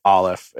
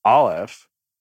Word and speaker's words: Aleph: We say Aleph, and I know it's like speaking actual Hebrew, the way you said it. How did you say it? – The Aleph. – Yeah. So it's the same, different cultural, slightly different interpretations, but it's A-L-E-P-H in the English language is how you'd spell it.